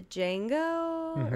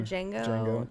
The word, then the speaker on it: Django: I've seen the Django or Django. Django Unchained. – The D is silent.